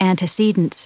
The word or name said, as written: antecedence